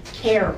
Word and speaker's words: Kerr: There's a facility in Arizona, and it's pronounced CARE, but it's spelled K-E-R-R, Cultural Center.